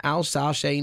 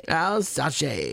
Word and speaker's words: Alsace: After the liberation of Buchenwald, Brasda settled in Alsace, northeastern France. I have butchered that so badly, no doubt. – Alsace, in May of 1945, and he lived there for the rest of his life.